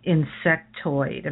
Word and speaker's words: insectoid: Like, what's an insectoid? Am I saying that right? – Mm-hmm. Yes.